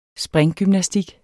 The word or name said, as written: springgymnastik